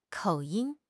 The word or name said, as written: 口音